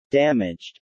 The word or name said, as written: damaged